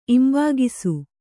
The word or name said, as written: ಇಂಬುಕೈಸು